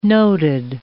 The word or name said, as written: noted